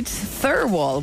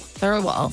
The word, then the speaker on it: Thirlwall: Thirlwall. – Thirlwall. – T-H-I-R-L-W-A-L-L. – Thirlwall. – Thirlwall. – You don't necessarily pronounce the L in the middle.